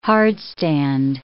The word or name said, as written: hardstand